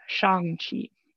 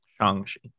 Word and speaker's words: Shang-Chi: I guess only thing I know is the name, and as someone who has studied Chinese for 7+ years and should know how to pronounce things from the romanization of it, it should be Shang-Chi. – Shang-Chi.